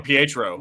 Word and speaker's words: Pietro: Who's Petro? – Quicksilver. – Pietro. – Yeah, Pietro.